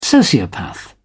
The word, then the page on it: sociopath: British English